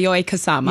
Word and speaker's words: Yayoi Kusama: Yes, Yayoi Kusama.